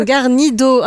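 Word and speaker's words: Guarnido: Donc Black Sad, euh, j'espère que je vais bien le prononcer, Juan Diaz Canales et Juanro Gardino. ok. Non, Garnido. Ah mince, j'ai fait une photo que je corrige sur le, sur le blog. C'est Garnido.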